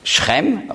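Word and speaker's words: Schrem (שכם): Sichem, auf Hebräisch spricht man aus Schrem, aber es ist das Gleiche.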